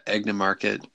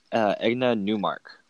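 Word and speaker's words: Egna-Neumarkt: Obviously, I don't think anyone emailed us, but you've been pronouncing it wrong. It's Egna Newmark. – Okay. – Not market.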